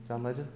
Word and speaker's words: John Legend: Did I say it right? John Legend? Yeah.